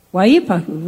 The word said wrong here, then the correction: Waipahu: right? Hawaii? Do that again, Terry Lynn. Waihapi? Waipapu. Okay. I'm so sorry. These are, yeah, Waipahu. I got it.